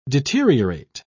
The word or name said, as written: deteriorate